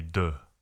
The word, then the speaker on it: de: Well, it would be de. – De. – Yeah, de Provence.